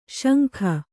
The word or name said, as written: ಶಂಖಿ